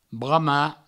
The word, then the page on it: bramar: brama